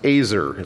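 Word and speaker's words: Ezer (עזר): In Hebrew, the word for helper used in Genesis 2:18 and 20 is the word pronounced Ezer. It's spelled E-Z-E-R.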